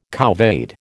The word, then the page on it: couvade: American English